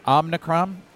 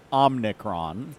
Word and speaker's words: Omicron: Okay, so there is a truly incorrect pronunciation, which is Omicron. – Right. – Or Omicron, which Biden mistakenly was doing. – 30330. – There is Omicron, which is the accepted English pronunciation, which is very common.